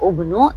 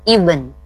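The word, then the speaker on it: ibn (ابن): Bukan seperti teori ya, huruf ketiganya berharokat dhamma dibaca ubnu, tapi dibacanya ibn, ibn ya, atau ibnu dibaca terusnya ya. Kalau diberhentikan ibn, ya ya.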